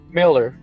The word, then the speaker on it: Millar: It's Mark Miller. It's Mark Miller, but it looks like it's spelled like Millar.